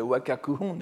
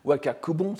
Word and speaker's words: Wakakuhon: En particulier, il y en a un qui s'appelle le Wakakuhon, n'est-ce pas, qu'on prononce aussi Wakakubon, c'est-à-dire Hon, c'est Shina, n'est-ce pas, les Hin, n'est-ce pas.